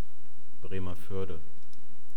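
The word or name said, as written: Bremervörde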